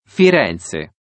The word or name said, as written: firenze